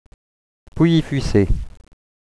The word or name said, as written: Pouilly-Fuissé